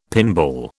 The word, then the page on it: pinball: British English